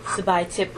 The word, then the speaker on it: Sabaythip: Sabai Tip Umsu. Would you like me to spell it? – Please. – S-A-B-A-Y-T-H-I-P. Umsu. O-U-M-S-O-U.